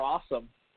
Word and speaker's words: Ossum: I know a Dr. Awesome. It's spelled O, like, it's like O-S-S-U-M or something, but it's pronounced Awesome.